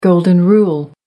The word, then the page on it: golden rule: American English